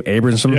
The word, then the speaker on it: Abramson: So it's closing in, and then Seth Abramson, is that how you say his name? Abramson? – Yeah.